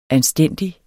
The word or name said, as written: anstændig